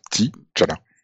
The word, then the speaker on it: T'Challa: C'est comme ça qu'on prononce. T'Challa.